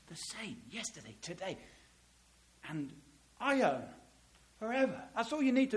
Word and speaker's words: aion: It's spelled A-I-O-N, ion. It's the word we get the English word eon.